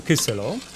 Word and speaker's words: kyselo: Jajecznicę z kolei dodamy do naszego gotującego się kyselo. A ten akcent na pierwszą sylabę jest dlatego, że w języku czeskim zawsze się akcentuje na pierwszą sylabę, w odróżnieniu od języka polskiego.